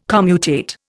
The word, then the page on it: commutate: Indian